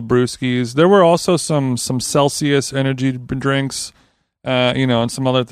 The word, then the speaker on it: Celsius: It's pronounced Celsius.